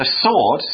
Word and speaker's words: sword: A sword spelled S-W-O-R-D, we don't pronounce the W, it's S-W-O-R-D, sword.